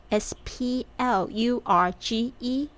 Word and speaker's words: splurge: 注意一下 p 的发音在这边变成了 b 的声音，它是 s p l u r g e splurge。